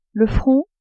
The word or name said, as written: front